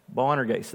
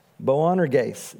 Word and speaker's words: Boanerges: Bo, I'm, I'm, almost, Boan Ergase. Boanerges, there we go. I practiced that several times and knew I was still going to butcher it. Boanerges, that is sons of thunder.